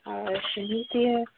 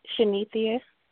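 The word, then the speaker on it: Shanethia: Hi, my name is Shanithia. Do you need my birthday? – What's your name? – Shanithia.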